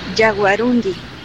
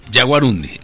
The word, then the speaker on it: yaguarundí: Pues nosotros decíamos por el acento yaguarundí, sin embargo... – Es así. – Es yaguarundí. – Yaguarundi.